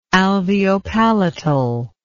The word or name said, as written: alveopalatal